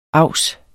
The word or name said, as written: avs